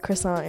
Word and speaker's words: croissant: Croissant, yeah. – Croissant is how you say it.